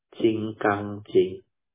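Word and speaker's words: Jingang Jing: They pronounce it as Jing Gang Jing. Diamond is Jing Gang. So, Diamond Sutta is Jing Gang Jing.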